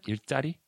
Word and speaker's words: 일자리: And it's 일자리, mm-hmm. not the double J. – Right, right, but right. – I, but don't you pronounce it kind of like that? 일자리? Yeah, 일자리.